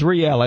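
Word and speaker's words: Rielle: Is it Riley? Raleigh? Riel? – I think it was Riel. I think we all agreed on that pronunciation yesterday.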